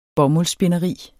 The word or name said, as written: bomuldsspinderi